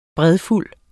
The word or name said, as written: bredfuld